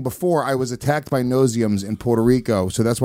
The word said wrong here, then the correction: no-see-ums: You're saying it wrong. – No-see-ums. – No-see-ums. – They're little... – Because you can't see them.